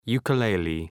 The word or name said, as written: ukulele